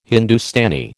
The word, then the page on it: Hindustani: American English